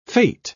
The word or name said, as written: fete